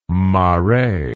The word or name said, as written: Marais